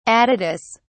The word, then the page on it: aditus: British English